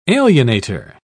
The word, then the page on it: alienator: British English